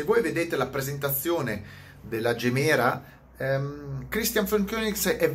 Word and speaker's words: Gemera: La Kon- Koenigsegg, Christian, Christian von Koenigsegg ha presentato la eh, Gemera o Gemera, dipende come, come, volete pronunciarla, Gemera.